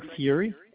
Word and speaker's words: Fury: Hi, this is Craig Fury. F-U-R-Y is the last name.